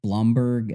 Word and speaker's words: Blomberg: First, Craig, I believe his name is pronounced Blomberg. It's B-L-O-M-B-E-R-G.